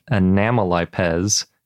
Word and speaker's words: Anomalipes: And they named it Anomalipes zhaoi, and Anomalipes comes from anomalous, which is why I'm pronouncing it that way sure other people have different opinions.